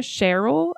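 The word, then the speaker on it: Cherrill: Virginia Sherrill, I think, is how her last name would be pronounced. – Sounds right.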